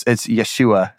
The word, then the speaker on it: Yeshua: But it's Yeshua. So that's Jesus' name pronounced in Aramaic.